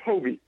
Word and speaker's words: Hobie: It's Hobie, just with a hard O, Hobie.